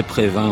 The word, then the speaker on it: Previn: De la puissante bande originale composée par André Prévin, ou André Previn selon la prononciation,